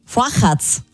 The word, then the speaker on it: Fochatz: Fochatz spricht man es aus, ein zopfähnlicher Wecken mit Anis, den man aber pikant mit Osterschinken und Creme essen kann.